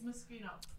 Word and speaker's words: Moschino: It's Moschino. – Fucking G. That's how do you say it? – Moschino.